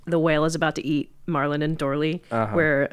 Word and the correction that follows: Dory: Marlon and Dory, and Dory is like speaking whale, and it's captioned as Dory as Ahsoka and Hu Yang as Marlon.